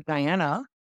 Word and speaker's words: Diana: Mouse could not pronounce my name, so she would always say Danny instead of Diana.